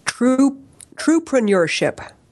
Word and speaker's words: Truepreneurship: One thing that you and I were talking about, you had created this word called, and I can never say it right, it's a tongue twister for me, trueership. How do you say that? – Truepreneurship.